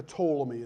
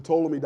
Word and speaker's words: Ptolemy: And so we get the Ptolemy. It's actually spelled with a P, P-T-O-L-E-M-Y, if you're taking notes.